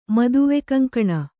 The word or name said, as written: ಮದುವೆಕಂಕಣ